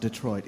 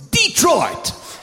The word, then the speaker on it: Detroit: It's not Detroit. It's Detroit!